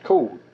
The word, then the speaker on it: Cord: Our original name is C-O-R-D, Cord. – C-O-R-D, Cord. – Right. – Okay. – But they pronounced it as Code.